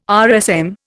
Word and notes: RSM: Indian

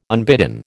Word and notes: unbidden: American English

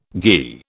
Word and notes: ghi: British English

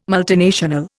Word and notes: multinational: Indian

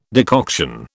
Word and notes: decoction: British English

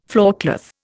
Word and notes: floorcloth: Indian

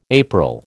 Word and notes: April: American English

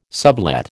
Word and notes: sublet: American English

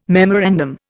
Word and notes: memorandum: Indian

